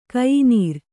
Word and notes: ಕಯಿನೀರ್: kayinīr

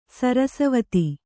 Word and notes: ಸರಸವತಿ: Kannada

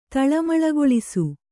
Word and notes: ತಳ್ಳಮಳಗೊಳಿಸು: taḷḷamaḷagoḷisu

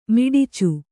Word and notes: ಮಿಡಚಿ: kannada

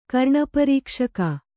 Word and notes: ಕರ್ಣಪರೀಕ್ಷಕ: Kannada

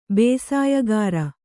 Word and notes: ಭಾಷ್ಯಕಾರ: bhāṣyakāra

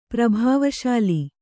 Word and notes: ಪ್ರಭಾವಶಾಲಿ: Kannada

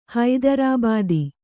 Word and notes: ಹೈದರಾಬಾದಿ: haidarābādi; kannada

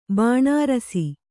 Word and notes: ಬಾಣಾರಸಿ: kannada

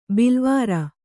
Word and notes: ಬಿಲವರ: bilavara

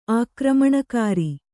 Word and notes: ಆಕ್ರಮಣಕಾರಿ: Kannada

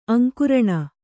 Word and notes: ಅಂಕರಣಿ: aŋkaraṇi

